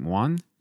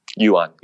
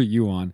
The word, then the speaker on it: Yuan: Dr. Christopher, how do I say his last name? Juan? Yan? – Yuan, yeah. – I did it wrong both times. Dr. Christopher Yuan.